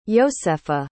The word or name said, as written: yosefa